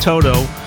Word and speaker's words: Toto: It's Toto, pronounced Toto.